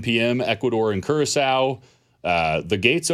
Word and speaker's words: Curaçao: It is Curaçao.